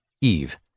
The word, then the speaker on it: Ivi: Some have also stated that the woman's name was Ivi, which would be by them pronounced as if written Eve.